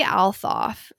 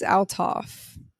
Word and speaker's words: Althoff: This Bobbi girl, Bobbi Althoff. Is that how you say it? – I think it's Althoff. – Althoff.